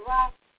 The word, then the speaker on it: RAK: It's Rock, R-A-K, it's an acronym, but it's pronounced Rock.